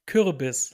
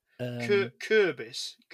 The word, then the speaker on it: Kürbis: How do you say it? – Kürbis. Like, it's like the U with the... – Kürbis.